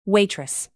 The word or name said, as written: waitress